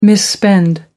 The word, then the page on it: misspend: American English